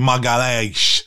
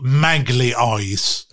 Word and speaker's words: Magalhães: Ni vet, Gabriel, backen i Arsenal, han heter ju Magalhães. Men han kan... I Arsenal-podden så heter han ju Yeah, I like Gabriel Magly Eyes. Och det låter ju mer som någon som har ögon som en skata eller någonting. Hello you fucking Magly Eyes, look here! Den jävla otroligt dåligt uttal.